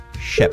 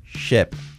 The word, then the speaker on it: ship: Escrito S-H-I-P, ship. Cuidado, no es ship, es ship. Ship, así, ship. Sheep con I sería oveja. Así que a partir de ahora, la I como una E.